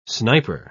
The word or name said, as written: sniper